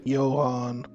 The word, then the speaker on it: Johann: Johann.